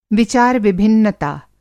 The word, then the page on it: विचार-विभिन्नता: Hindi